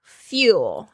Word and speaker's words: fuel: 接下来是 surround with, surround, S-U-R-R-O-U-N-D, 然后 with, W-I-T-H, 是环绕者。 fuel, 是给予能量， F-U-E-L, fuel. 注意一下它 L 的声音，不要念成 R。